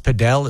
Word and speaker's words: padel: It looks like paddle, but it's pronounced padel.